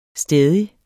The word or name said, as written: stædig